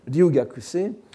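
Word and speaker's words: Ryugakuse (留学生): Kukai, de son côté, était ryugakusho », qu'on écrirait en japonais, prononcerait en japonais moderne Ryugakuse,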